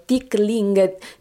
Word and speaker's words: Tickling: È appena uscito il vostro nuovissimo singolo, Tickling, dimmi se dico giusto la pronuncia, ecco. – Giusto, Tickling.